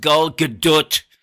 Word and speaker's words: Gal Gadot: Warner Brothers has established a new Wonder Woman College Scholarship with the inaugural recipient receiving it from Gal Gadot, or as she says it's pronounced, Gal Gadot.